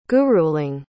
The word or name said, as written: guruling